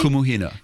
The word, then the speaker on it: Kumuhina: Kumuhina, am I pronouncing that correctly? – Kumuhina.